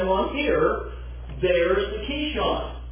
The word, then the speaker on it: Kishon: and what we're going to do is kill them there at the Kishon. That is how you pronounce that in Hebrew. The Kishon Brook.